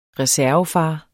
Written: reservefar

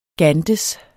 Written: gantes

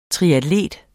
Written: triatlet

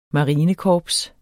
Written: marinekorps

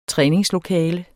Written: træningslokale

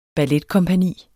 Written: balletkompagni

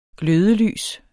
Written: glødelys

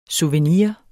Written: SOUVENIREN

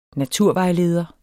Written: NATURVEJLEDERE

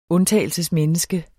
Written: undtagelsesmenneske

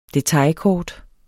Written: detailkort

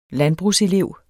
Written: landbrugselev

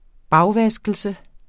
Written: bagvaskelse

